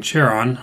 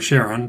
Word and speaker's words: Charon: An example of these would be Cerberus, the three-headed dog in Greek mythology, or maybe even Charon, or Charon, depending on how you want to pronounce that.